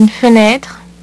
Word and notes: une fenetre: French

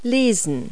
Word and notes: read: verb

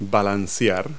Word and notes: roll: verb

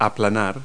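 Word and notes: roll: verb

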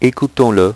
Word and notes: ecoutons-le: French

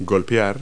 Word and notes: putt: verb

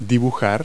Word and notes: draw: verb

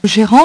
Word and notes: gerant: French

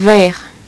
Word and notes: verre: French

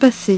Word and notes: passer: French

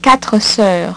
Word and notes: quatre soeurs: French

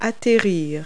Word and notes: land: verb

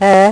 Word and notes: Ë: French

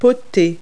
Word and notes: putt: verb

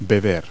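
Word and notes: drink: verb

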